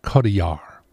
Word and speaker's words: Marion Cotillard: Marion Cotillard, the French actress whose name is pronounced actually Cotillard. I was close.